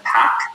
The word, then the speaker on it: Pak: My name is Kevin Pack. Last name is spelled P-A-K.